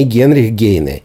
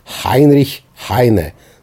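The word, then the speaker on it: Heinrich Heine: Не Генрих Гейне, нет, неправильно. Хайнрих Хайне. Запомните, по-немецки это произносится Хайнрих Хайне.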